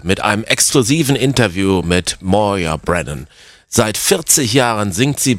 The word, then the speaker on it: Moya Brennan: Yeah, it is Moya Brennan. The reason that I've had to change my Gaelic name is... – Ja, man spricht meinen Namen wirklich Moya Brennan aus. Ich habe die Schreibweise zu Moya, M-O-Y-A, geändert, weil alle meinen gälischen Namen falsch aussprachen, erzählt Moya.